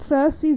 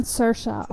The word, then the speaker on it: Saoirse: Sie spricht sich nicht Cersei, sondern Saoirse aus.